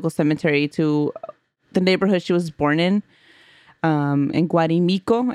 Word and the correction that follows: Guarico: Of course I said it wrong. Guarico. – Oh, you like added. – I added an M and an I.